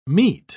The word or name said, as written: meat